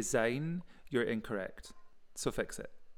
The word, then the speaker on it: Auld Lang Syne: Also, thank you for pronouncing Auld Lang Syne correctly. – Thank you. Did I? Mm-hmm. – You did. If people say Auld Lang Syne, that's incorrect. – So I said it right. Yeah. Thank you very much. – You said Syne, which is correct.